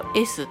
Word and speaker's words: Es: で E フラットのことをドイツ音名だと S って、うん、 ES で S って読むので。